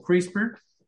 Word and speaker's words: CRISPR: Lo pueden buscar como CRISPR. Esto es una cosa muy, muy, muy importante y reciente, se pronuncia así, es, um, son unos clusters, es, em, de, unas, secuencias de ADN que se llaman ah, palíndromos.